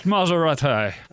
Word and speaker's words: Maserati: It's pronounced Maserati.